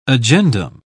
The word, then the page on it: agendum: British English